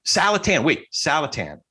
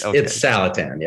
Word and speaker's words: Saletan: Saletan. Like Callahan. Saletan. – Yeah. Or right. Taliban. That's the other way you can. Okay. Of course, if you're actually going to pronounce it, it's Taliban. So that messes it all up. Oh, but it is. It's Saletan. Yeah.